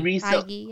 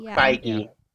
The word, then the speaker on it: Feige: Kevin Fee? How do you say his last name? – Feige. – Feige.